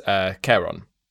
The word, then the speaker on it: Charon: And the classic one is Charon, which I pronounced earlier erroneously Charon, because I forgot my Greek.